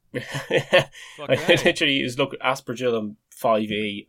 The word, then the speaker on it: aspergillum: Aspergillum.